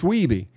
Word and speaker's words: Wiebe: Steve Wiebe, and by the way, after all this time, he doesn't know how to say his name, so he calls him Wiebe. – Wiebe. – He says, Steve Wiebe has come to three events to prove himself. – But his actual name is Wiebe? – It's Wiebe.